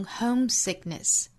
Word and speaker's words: homesickness: 那 homesickness 这个词重音在第一个音节哈，我们注意读成 homesickness， homesickness， in homesickness I'm drowned。